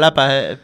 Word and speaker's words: Xalapa: Jalapa, se pronuncia. En Xalapa, perdón. – Sí, es como el chile jalapeño, precisamente por eso es jalapeño.